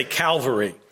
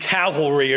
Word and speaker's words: cavalry: So when you're a Christian, you always want to say Calvary. Calvary and cavalry are two different things.